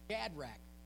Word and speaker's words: Shadrach: And he got together with Radshack, Meshach, and Abednego. Am I saying it wrong? Radshack, Meshach, and Abednego, is that? Shadrach, Meshach. Yeah.